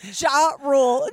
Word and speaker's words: Ja Rule: So, Jerul, let's get through this. Jerul! How do you say it? Ja Rule. Ja Rule. – That's ja Rule.